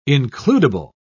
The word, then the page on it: includable: British English